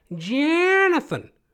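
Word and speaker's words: Johnathan: Because I spell mine with an H. Different pronunciation. Gives it a little bit of that Jonathan. It's pronounced Jonathan. He goes, what? Say it with me. Is it J-O-H-N or J-O-N?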